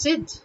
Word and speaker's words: zid: In Hindi they cannot generally pronounce the z word, they would end up saying jid, you know. But it's zid.